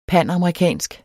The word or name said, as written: pandemi